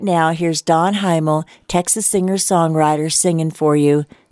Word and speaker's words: Hymel: Okay, so that reminds me of what I'm going to play now, a song from Don Heimel's CD. Don is a Texas singer-songwriter, and I hope I'm saying his name right. It's Don, H-Y-M-E-L.